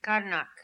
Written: Karnak

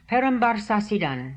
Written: Perambarrsasidaan

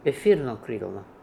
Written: Etherokrilno

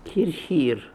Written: Hirr Hirr

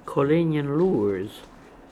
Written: Kolenian Loors

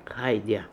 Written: Haidia

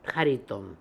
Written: Hariton

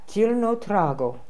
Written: Zilnotrago

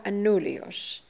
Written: Anulios

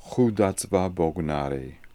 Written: Hoodazbabognari

